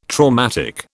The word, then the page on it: traumatic: British English